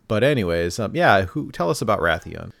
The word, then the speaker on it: Rathian: Yep, Rathian.